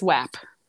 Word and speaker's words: WAP: Is it WAP? How do you say it? – I would say it's WAP. – Okay. – That's how I would pronounce it.